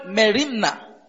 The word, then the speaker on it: merimna: The word cares, as used here, is the Greek word merimna. The word cares, as used here, is the Greek word merimna. That is spelled M-E-R-I-M-N-A.